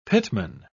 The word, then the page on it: pitman: British English